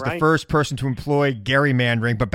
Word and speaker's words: gerrymandering: Because my wife is a direct descendant of Elbridge Gerry. And that's how you pronounce it. It's actually gerrymandering. Gerrymandering.